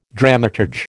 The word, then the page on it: dramaturge: American English